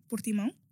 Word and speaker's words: Portimão: So Afro Nation will be in Portimão. – Mm-hmm. Say that again. – Portimão.